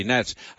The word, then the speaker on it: gnats: And gnats, by the way, for those who have never heard of them, is spelt with a G at the front. That's weird. G-N-A-T, gnats.